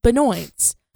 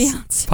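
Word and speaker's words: Beyoncé: or as I heard someone pronounce it once, Benoit's. – Um, yes, Beyonce.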